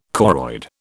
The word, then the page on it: choroid: British English